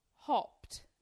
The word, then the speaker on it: hopped: So, you know, like crazy stuff like if you say hopped, even though it's spelled ED, it's got a T at the end of it.